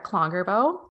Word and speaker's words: Klongerbo: So again, my name is Sarah Klongerbo, and you can thank my husband for that last name.